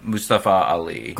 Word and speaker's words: Mustafa Ali: The Intercontinental Championship, Gunther and Mustafa Ali.